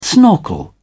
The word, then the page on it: snorkel: British English